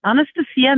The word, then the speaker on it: Anastasia: This is Anastasia McNabb from Fort Wayne, Indiana. – Anastasia, you said? – Yes. – Is that spelled like Anastasia? – It is, but it's the Greek pronunciation.